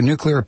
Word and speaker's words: nuclear: And we learned to pronounce nuclear with only two syllables.